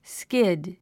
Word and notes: skid: skíd; スキド

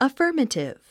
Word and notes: affirmative: əfə́ː r mətiv; アッファマチィブ

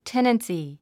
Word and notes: tenancy: ténənsi; テナンスィー

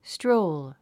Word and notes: stroll: stróul; ストロウル

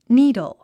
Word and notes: needle: níːdl; ニードゥ

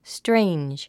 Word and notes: strange: stréindʒ; ストレェンジ

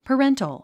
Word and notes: parental: pəréntl; パレントォ

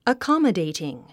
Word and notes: accommodating: əkάmədèitiŋ; アカマデイティン

